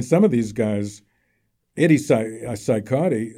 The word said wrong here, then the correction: Cicotte: however you pronounce his name, Cicotte,